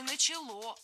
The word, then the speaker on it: начало: получила вольность дворянская, то есть с 1862 года активное строительство начало вестись. – Не начало, а начало. Ударение в этом глаголе, опять же, не ставим на окончание. Начало, брало, запомнили.